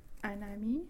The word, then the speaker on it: un ami: The word for friend we had at the very start is un ami.